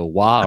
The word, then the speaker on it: Waugh: It's the last name is W-A-U-G-H. Waugh. Woo. Woof. – Got me. – If you live in Amelia, it's Clow or Clough Pike, so Wah or Woof.